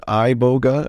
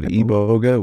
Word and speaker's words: iboga: People pronounce it iboga. I pronounce it iboga, which spelled I-B-O-G-A.